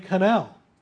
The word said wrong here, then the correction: Connell: These images were created, as Connell Connell, I guess is correct pronunciation, Kelli Connell.